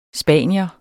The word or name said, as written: spanier